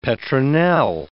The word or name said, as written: petronel